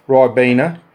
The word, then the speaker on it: Rybakina: That Rybakina, or however you pronounce her name. – Rybakina.